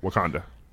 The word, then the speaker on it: Wakanda: Yeah, Wakanda.